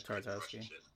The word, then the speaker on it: Tartakovsky: I don't know how to say his name. – Yeah, Tartakovsky.